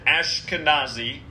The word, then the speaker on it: Eskenazi: And it's spelled a little bit different, Eskenazi, but she pronounced it Ashkenazi.